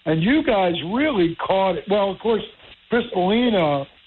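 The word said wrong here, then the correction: Cristalino: am I saying that correctly? – Crystalino. – Yeah, he was the executive chef before you at Smithville, and he won first prize in 2014 in Jersey Gumbo.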